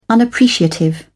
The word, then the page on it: unappreciative: British English